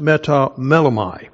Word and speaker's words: metamelomai: It's pronounced metamelomai.